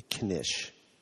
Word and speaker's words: knish: It's such a cute name, the knish. – Yes, well, thank you. I think so, too. It's important to note it's one syllable and that the K is pronounced. That's knish.